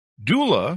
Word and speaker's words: doula: She spent much of her career as a preschool teach director, a birth doula.